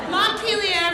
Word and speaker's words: Montpelier: So Montpelier, Vermont, yes. Concord, New Hampshire? Yes, okay. So we have the provider, Montpelier, thank you.